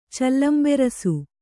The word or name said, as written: ಚೆಲ್ಲಂಬೆರಸು